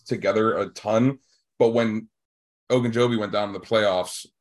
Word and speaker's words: Ogunjobi: They did lose Larry Ogunjobi, who was huge for them.